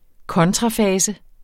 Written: kontrafase

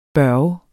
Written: børge

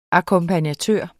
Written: akkompagnatør

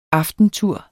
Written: AFTENTUREN

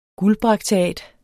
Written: guldbrakteat

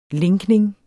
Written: linkning

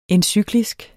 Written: encyklisk

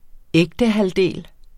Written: ægtehalvdel

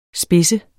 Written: SPIDSENDE

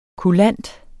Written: kulant

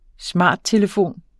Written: smarttelefon

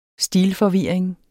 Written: stilforvirring

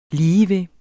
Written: ligeved

